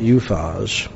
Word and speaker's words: Uphaz: whose loins were girded with fine gold of Uphaz. And that's how some pronounce it. Some pronounce it Uphaz.